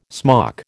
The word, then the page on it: smock: American English